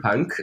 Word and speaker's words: Pank: Or also Peter Punk, but Peter spelled with an I, P-I-T-E-R, and then Punk, P-A-N-K.